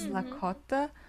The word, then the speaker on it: Lakota: how actually to pronounce the name of the language. So it's Lakota.